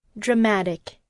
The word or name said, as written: dramatic